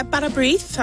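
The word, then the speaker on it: bara brith: Is it Barabrith? How do you say that? – Yeah, Barabrith, I would say.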